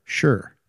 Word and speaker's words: Shure: And the new microphones are going to be from the company's called Shure. Mm-hmm. You say that right? You say it. – Sure. – Sure. – Sure. – Sure. It's, sure.